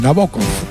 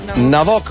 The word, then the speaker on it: Nabokov: Nabokov. – Nabokov.